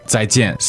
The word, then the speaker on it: 再见: 再见. – So you're going to have that reinforced with every podcast you listen to. Remember the pronunciation point about the Z.